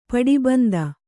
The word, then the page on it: ಪಟ್ಟಬಂಧ: paṭṭa bandha